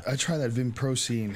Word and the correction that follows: vinpocetine: How do you say it? – This is vinpocetine right here, which is in this.